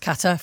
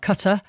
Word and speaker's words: Qatar: We've had questions about how you should pronounce Qatar for a start. Qatar, as we now know.